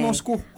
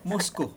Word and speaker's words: Moscow: Moscow. Wala W. Moscow.